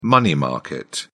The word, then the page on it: money market: British English